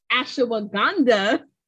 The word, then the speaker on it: ashwagandha: Am I saying it right? – Ashwagandha. I believe I looked at the pronunciation. I believe that's correct.